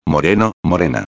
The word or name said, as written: moreno-a